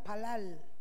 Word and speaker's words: palal: En el idioma hebreo, interceder significa, se, pronuncia palal, significa orar, interceder o mediar entre dos partes.